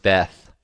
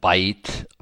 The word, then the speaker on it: Beth: And when you see in Hebrew or in English the word Beth, I think it's bet is how I've heard it pronounced the most. But I've heard some people say like bite, bet.